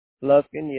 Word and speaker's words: Lufkin: Lufkin, yes, sir.